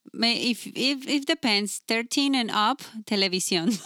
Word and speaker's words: televisión: And how do you say television? – Televisión.